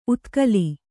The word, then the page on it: ಉತ್ಕಲಿ: utkali